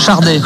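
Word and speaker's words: Sade: Par contre, ça se prononce Chardé, avec un R qui n'existe pas, mais en prenant on dit Chardé normalement. Et je crois que d'ailleurs, elle le dit, oui, elle le dit elle-même, Chardé.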